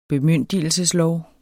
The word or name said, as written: bemyndigelseslov